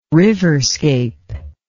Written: riverscape

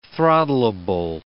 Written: throttleable

